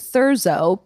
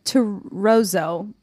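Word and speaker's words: Thurzó: It's spelled like Thurzo, but Terzo.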